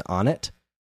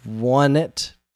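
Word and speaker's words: Onett: I've always been torn on how to pronounce this, because I've always heard it pronounced as Onnit, because it's O-N-E-T-T, but it's... there's like a theme with the town names. It's Onnit, Tucson, Threed, and Foreside. So I kind of want to call it Onnit.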